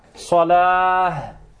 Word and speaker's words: الصلاة: Farq, isma'u, baina salat wa salah bilha, antuq bilha. Idha qulta salat, ma'na an-nar. Fa idha qulta hayya ala salat, أي حي على النار حي على الصلاة تنطق بالهم بالهاء فمعناه صحيح. Ils disent حي على الصلاة et ils s'arrêtent sans prononcer ha.